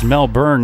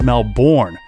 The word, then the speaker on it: Melbourne: Adam made sure to tell me that it's Melbourne, not Melbourne. So I must have said it wrong last time, but just give me a break, people.